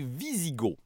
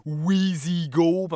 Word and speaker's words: Wisigoths: Bref, Théodose va mettre fin aux guerres gothiques en 382 et les Goths qui se sont installés en Empire vont devenir les Visigoths. Ça s'écrit avec un W, mais ça se prononce comme un V. Là. Faites pas comme moi les appeler les Wisigoths pendant des années, comme si c'était une marque de petit yogourt en bouteille à boire sur le pouce en route vers l'école.